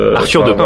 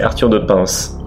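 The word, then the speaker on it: Arthur de Pins: D'ailleurs, Michael Owen nous indique que dans Arthur de Pin, le S se prononce, donc c'est Arthur de Pince.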